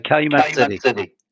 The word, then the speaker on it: Calumet: And is it Calumet? Am I saying that correctly? – Calumet City. – Calumet City, Illinois. – Yes.